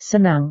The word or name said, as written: senang